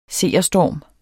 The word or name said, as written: SEERSTORMEN